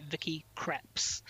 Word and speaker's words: Vicky Krieps: So my number three, was Vicky, and I'm pretty sure I got this right, Vicky Krieps, how to pronounce her surname, from Phantom Thread.